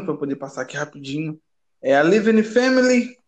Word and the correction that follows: Levine: A, peraí, eu tô falando certo? Living Family Racing. Levine. É,